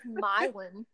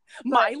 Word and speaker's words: Milan: Did I say that right? – It's Milan. – Milan? – Only people from Milan know that. – It's so funny because I read that and I was like, I need to make sure that I'm going to say this right. I totally didn't.